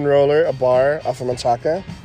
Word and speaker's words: Manchaca: The correct pronunciation is Manchaca. – Manchaca, yeah. – Manchaca. Well, I'm down with that, yeah. – It ends in an A.